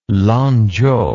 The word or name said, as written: Lanchow